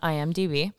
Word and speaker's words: IMDb: I always say it wrong, so I want to make sure I had it right. Yes, on IMDb.